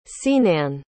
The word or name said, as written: Senán